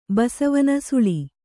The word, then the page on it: ಬಸವನ ಸುಳಿ: kannada